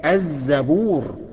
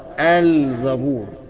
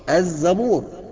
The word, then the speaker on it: الزبور: الزبور، الزبور، النطق الخطأ بقى الزبور، انما النطق الصح الزبور بإدغام اللام في الزاي.